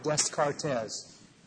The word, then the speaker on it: Descartes: Now, it's spelled like Descartes, but it's pronounced Descartes, just like Des Moines is pronounced Des Moines.